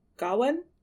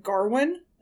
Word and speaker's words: Gawain: Gawain, I think is his name. But some people in the film pronounce it R in it, so they're like Garwin.